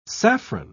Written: saffron

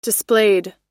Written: displayed

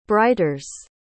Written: brighters

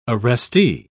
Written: arrestee

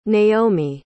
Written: naomi

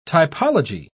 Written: typology

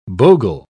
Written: bogle

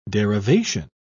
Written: derivation

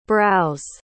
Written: brows

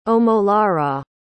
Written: omolara

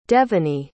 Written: devinee

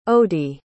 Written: ody

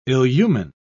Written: illumine

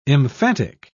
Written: emphatic

